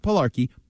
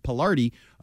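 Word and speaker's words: Palardi: Yeah, not Pilarki. Pilardi. I think I'm saying it right.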